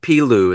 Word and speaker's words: Pilu: But the latest album, and hopefully I'm pronouncing this right, The Hidden World of Pilu, is that how you say it? – Correct.